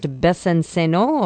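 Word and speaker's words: Besancenot: It's Olivier, I guess it would be pronounced Bessin-Senot, or? – Yes, that's right.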